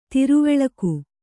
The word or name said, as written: ಧ್ರುವಲೋಕ